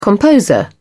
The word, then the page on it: composer: British English